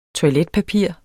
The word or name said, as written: toiletpapir